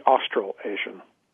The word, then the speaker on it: Australasian: the Australasian Talent Conference. Is that the way to say it? – The Australasian.